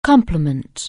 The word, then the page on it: complement: anglais